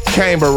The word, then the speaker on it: Cambr: Cambr rhymes with chamber.